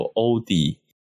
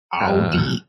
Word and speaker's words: 奥迪: 就是包就是现场官方就是反正就是一些代表在介绍的时候有提到有欧迪，诶，另另外一个发音叫什么？ – 奥迪，欧迪，哦，正正正确正确是奥迪嘛？嗯，然后我们之前也讲说，有台湾有些人会发欧欧迪，嗯，哦，欧迪就不太对，诶，不能讲不太对，应该说就是跟德国原文跟德国的发音不太像，我们不能说它错的、嗯、啊。